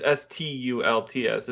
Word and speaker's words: Stults: It's you could pronounce it Slutz the way it's read, but anyways, because it's Sultz. So, It's Stults. It's Stults.